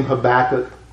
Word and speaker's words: Habakkuk: Most people pronounce his name Habakkuk.